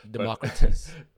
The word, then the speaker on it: Democritus: name of Democritus or Democritus, or I don't know how to say it. – Democritus. – Democrates? – I'm pretty sure that's it, because it's Democritus. Yeah.